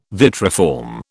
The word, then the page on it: vitriform: British English